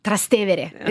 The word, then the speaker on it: Trastevere: Trastevere is the area. Yeah, I love it. – I think I'd be saying Trastevere. – Trastevere.